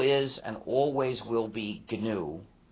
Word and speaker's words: GNU: But when it's the name of our system, the correct pronunciation is GNU. So please pronounce a hard G.